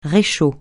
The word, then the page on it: réchaud: francais